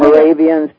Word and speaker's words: Moravians: Did I pronounce that correctly? – Moravians, absolutely perfect.